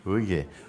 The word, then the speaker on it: Hugues: H-U-G-U-E-S, Sangha, in France. French, how you say? Hugues. Huh? Hugues. Hugues.